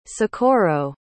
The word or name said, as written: socorro